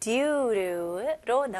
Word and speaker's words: due to: 여기서는 due, 그 다음에 to이지만, 원어민들은 이 to 단어는 조금 이렇게 약화시켜가지고 너무 to, 센 t 발음보다는 약화시켜서 루. due로 이런 식으로 발음합니다.